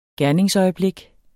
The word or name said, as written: gerningsøjeblik